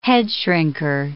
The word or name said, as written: headshrinker